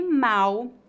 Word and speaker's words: mal: No Brasil, a letra L em final de sílabas é pronunciada exatamente como a letra U. Portanto, pronunciamos mal com U e mal com L.